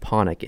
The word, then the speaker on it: Panik: It's Panic in, that's how it's spelled, P-A-N-I-K. – Right. – It's actually pronounced Panic in his home country.